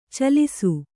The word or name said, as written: ಛಲಿಸು